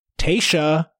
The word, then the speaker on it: Tayshia: Alex B., Erica, Katie, Kaylin, Sydney, Tayshia, which is how it's pronounced, A-B, Tayshia.